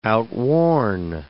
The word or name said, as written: outworn